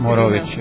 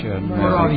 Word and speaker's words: Morović: Ne može dugačko, nego kratko. – Kako se akcentuje, kažete? – Morović. – Morović.